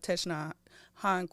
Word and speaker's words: Thich Nhat Hanh: And I feel like that Thich Nhat Hanh quote, I can never pronounce his name, like, smoothly. – No, I feel like you're doing it right.